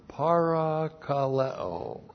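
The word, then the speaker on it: parakaleo (παρακαλέω): And then we have the verb parakaleo. P-A-R-A-K-A-L-E, long O, parakaleo.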